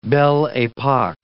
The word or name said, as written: belle epoque